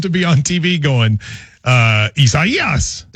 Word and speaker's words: Isaias: People are saying, Matt, you placed the syllable on the emphasis on the wrong syllable. It goes on the third syllable. So it's Isaias.